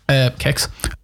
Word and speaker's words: CEX: What's it pronounced? – Kex. And we have to say it like that.